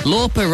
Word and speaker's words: Lauper: Lauper rhymes with pauper, which is one way of describing the street urchin, Oliver.